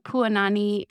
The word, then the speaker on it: Puanani: Puanani is actually Hawaiian for beautiful flower.